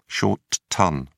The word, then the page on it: short ton: British English